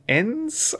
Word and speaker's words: Ennes: Speaking of mind-blowing fantasy, I've been reading a novel called The Works of Vermin by Hieron Enns, I think it's pronounced, E-N-N-E-S.